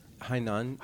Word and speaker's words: Hainan: Hainan.